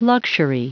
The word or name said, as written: luxury